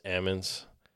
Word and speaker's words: almonds: but almonds, the L is silent, so it's almonds.